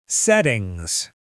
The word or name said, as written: settings